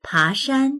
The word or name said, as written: pa2shan1